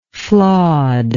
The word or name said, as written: flawedness